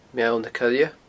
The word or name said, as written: Meall na Coille